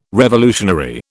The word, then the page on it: revolutionary: British English